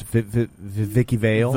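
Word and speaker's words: Vicki Vale: It's pronounced Vicky Vale. Vicky Vale.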